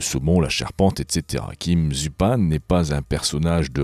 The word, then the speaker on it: Zupan: Kim Zupan, ou Zupan peut-être, ça s'écrit Z-U-P-A-N, mais comme le monsieur est originaire du Montana aux US, je dirais Kim Zupan.